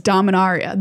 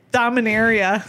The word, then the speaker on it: Dominaria: It is Dominaria. The very fanciest way to say it is correct. – As opposed to like Dominaria.